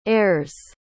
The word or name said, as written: airs